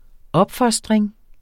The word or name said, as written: opfostring